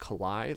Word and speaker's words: Calais: And then I think it's called, pronounced Calais. Like, I think it's a French city or something.